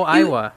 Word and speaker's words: Aiwa: And then I remember I had my beloved Iowa. Is that how you pronounce it? A-I-W-A? – Oh, Iowa.